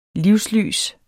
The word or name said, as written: livslys